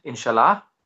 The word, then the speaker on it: inshallah: Ultimately, everything happens inshallah, if Allah willing.